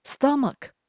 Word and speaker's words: stomach: Stomach 是指胃，或者是指肚子、腹部都可以。请留意它的发音，t 可以转成的会比较好念，stomach。后面的 ch 是发 k 的声音，大部分的几率是发 ch，但是呢，这边是发 k，请特别注意。